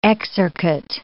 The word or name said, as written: exarchate